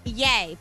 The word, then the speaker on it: Ye: The judge who approved Kanye West request to change his name, which is now going to be, now tell me if I said this right, is it Ye? – Yay for Kanye.